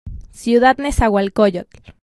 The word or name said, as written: Ciudad Nezahualcóyotl